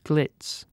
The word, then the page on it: glitz: British English